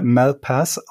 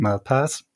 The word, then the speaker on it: Malpass: David, ich würde ihn mal Malpass aussprechen, ich bin mhm. mir aber nicht hundertprozentig sicher. Ja? – Würdest ja, du auch so sagen? Würde ich auch sagen, ja.